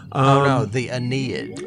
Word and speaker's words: Aeneid: Adamantium, it was actually first mentioned in the Enid, I think is the way you pronounce it, A-E-N-E-I-D, The Aeneid. – Aeneid.